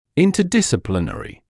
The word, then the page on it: interdisciplinary: [ˌɪntəˌdɪsɪ’plɪnərɪ]; [ˌинтэˌдиси’плинэри]